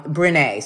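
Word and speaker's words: Brené: And then you've got this entire group of people like Glennon Doyle, like Breenie Brown, like um, Brene. Sorry, I'm totally pronouncing everybody's name wrong today.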